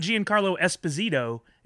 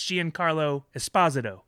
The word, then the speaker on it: Giancarlo Esposito: Yeah, they have a whole thing about, it's not Giancarlo Esposito, it's Giancarlo Esposito.